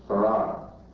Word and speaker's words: Fard: And I believe that the teacher of my father, whom you find in the Encyclopedia Britannica, under the name Farad, F-A-R-D, pronounced Farad.